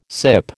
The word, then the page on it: sip: American English